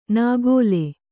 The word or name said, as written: ನಾಗೋಲೆ